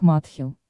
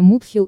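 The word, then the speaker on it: Muthill: Более того, автор, шотландец, произносит название городка Матил как «Матхил», но при этом готов поспорить, что местные, вопреки очевидному, произносят это название Мутхил или как-то так.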